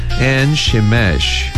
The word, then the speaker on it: 엔세메스: 먼저 엔세메스부터 엔시메쉬 엔시메쉬 이렇게 발음하시고 다음에 글릴롯은 골라일라스 잘 듣고 따라해보세요.